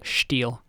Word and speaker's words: Stihl: They're like, no, it is Steel or Steel. – Steel? – Steel. S-T-I-H-L.